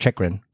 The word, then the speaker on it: Chychrun: That's pronounced Chychrun. – Chychrun. Oh, okay.